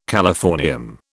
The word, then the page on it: californium: British English